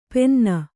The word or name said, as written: ಪೆನ್ನ